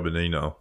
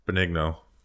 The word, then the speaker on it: Benigno: I think the craziest thing is that Robert Saleh spent 30 minutes on the phone with Joe Benigno. – Benigno. Nope. – I always get that name wrong.